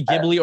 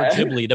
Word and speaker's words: Ghibli: It could be Ghibli or Ghibli depending on who you talk to.